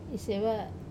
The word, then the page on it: seva: Wamesa